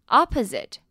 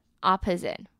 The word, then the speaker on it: opposite: 那比较特别是 opponent 跟 opposite 前面 OPPO 的发音不一样哦。 opposite 的第一个 O 是发 R，第二个 O 发短音 E，那 opponent 呢反而是在第一个 O 发短音 E，第二个 O 是发 O 的音， opponent， opponent， opposite， opposite。